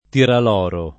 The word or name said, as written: tiraloro